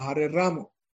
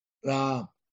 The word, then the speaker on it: Rama: La pronunciación es rama. No es la rama de un árbol. La primera A es más larga. – Rama. Y la segunda más chiquita.